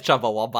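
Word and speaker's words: Chumbawamba: Chumby Wumby? – Oh yeah, it is Chumba Wumba.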